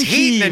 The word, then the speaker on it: Heaton: And you said Keaton, which is my Russian name. – I know. – I really was offended. It's Heaton in Ukraine, but it's Keaton when I'm in Moscow.